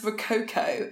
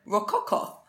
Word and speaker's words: Rococo: Also, how did Lovejoy pronounce Rococo in that piece? It went Rococo.